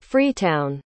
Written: freetown

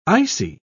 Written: icy

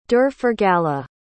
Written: derforgala

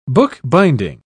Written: bookbinding

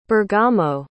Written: bergamo

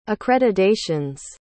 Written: accreditations

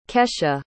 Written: kesha

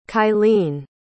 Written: kailene

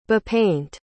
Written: bepaint

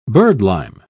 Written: birdlime

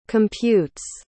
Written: computes

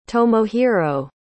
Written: tomohiro

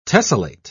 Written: tessellate

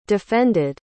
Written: defended

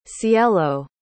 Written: cielo

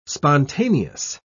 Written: spontaneous

